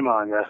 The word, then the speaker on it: manga: How is it pronounced? Manga or manga? – It's definitely manga.